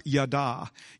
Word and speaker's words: yadah: It's actually a singular word in the original language, and it's pronounced yada, yada. And it's spelled Y-A-D-A-H, okay, but yada, right?